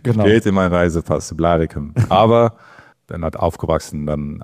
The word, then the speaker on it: Blaricum: Hilf mir bitte bei der Aussprache. Blaricum? Wie nennt man den Ort richtig? – Ja, Blaricum. Aber ja.